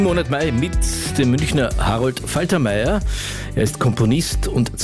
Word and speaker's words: Harold: Harold mit O und nicht mit A, wie es normalerweise hier in Deutschland üblich wäre.